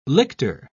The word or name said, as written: lictor